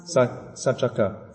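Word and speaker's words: Saccaka: Satchaka. Yeah. So in Pali, the C is pronounced like. Is pronounced like with a ch sound, yeah, ch sound, yeah, satchaka.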